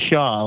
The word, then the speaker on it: Shaw: My name is Shaw. Like, you can't really mispronounce it. It's very hard to do.